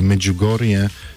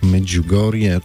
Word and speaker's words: Medjugorje: Polska wersja brzmi tak właśnie, Olu, jak wypowiedziałaś, czyli Medjugorje z takim miękkim dżib, tak Medjugorje.